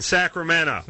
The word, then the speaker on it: Sacramento: Vote for Corolla sends a message to those fat cats in Sacramento.